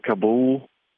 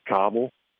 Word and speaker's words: Kabul: I understand we're all pronouncing it wrong over here. It's like these people I hear saying Ocala and stuff that we're saying right. – Kabul wrong. Is that right? We're saying. – I pronounce it Kabul. I think the proper term is Kabul.